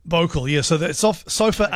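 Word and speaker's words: bouclé: Anyway, so this is, I think I'm pronouncing this properly. Bocal, B-O-U-C-L-E, Bocal.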